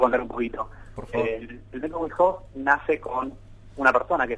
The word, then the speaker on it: Wim Hof: No, está muy bien pronunciado, método Wim Hof, como bien dijiste.